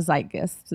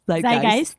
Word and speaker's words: zeitgeist: I do think that they're going to be a little bit more tapped into the zeitgeist this time around. – Into the what? – You know. I can't pronounce words. I'm a writer. Say it. – Zeitgeist.